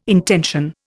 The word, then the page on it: intension: Indian